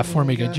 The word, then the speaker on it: Formiga: How'd you say his name? Formiga. – Yeah, Formiga. GCA Formiga.